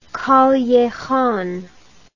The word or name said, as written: callejon